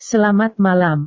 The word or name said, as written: selamat malam